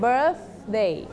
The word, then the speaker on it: birthday: Don't forget to pronounce this sound birthday okay เช็คด้วยนะคะมีครบไหมครบนะคะ Okay, next one is adjective.